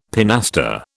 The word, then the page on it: pinaster: British English